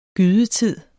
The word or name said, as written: gydetid